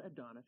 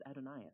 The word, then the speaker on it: Adonais: It's not Adonis, it's Adonais.